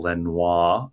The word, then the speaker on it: Lenoir: My man, Diomedor Lenoir, going to keep with the Parisian pronunciation this season for Lenoir.